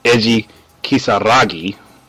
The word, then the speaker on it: Eiji Kisaragi: Eiji Kisagati. Oh, shoot, I, I did that backwards. Eiji Kisaragi, Goenitz, Mukai, and Oroch oh gosh, I, I know I butchered a lot of those names.